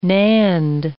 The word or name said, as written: NAND